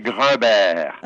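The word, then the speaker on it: Grumberg: Pronunciada la francesa es Grunberg. Se escribe Grunberg, pero es Grunberg.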